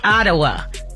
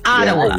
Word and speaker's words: Ottawa: Is how do you say it? – Ottawa. – It's Ottawa.